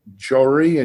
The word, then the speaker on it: Johri: Just as you said it just right. It's just as it's spelled, Johri. – Okay, so it's Sandeep Johri, yeah. – Sandeep Johri, I just want to make sure I didn't mangle it or butcher it.